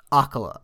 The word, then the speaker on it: Akkala: Uh, I think it's Akkala. I think the emphasis is on the A. – Okay. Or the first A, not just any A.